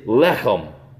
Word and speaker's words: lechem: Let me share that word with you so I pronounce it correctly. It is lechem.